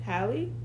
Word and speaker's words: Halle: But, like, we forgot Miss Holly. Holly Berry. I love Holly Berry. – Halle, I'm sorry. – It's not the same thing. Halle? You have to pronounce her name right. – Halle? – Mm-hmm.